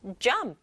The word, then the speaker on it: jump: 하지만 영어는 한 박자로 jump, jump 이렇게 한 박자로 발음하기 연습해 보세요.